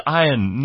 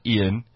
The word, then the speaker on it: Ian: His name is Ian, not Ian. Oh, Ian Ziering. – Even though it's spelled I-A-N.